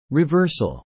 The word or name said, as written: reversal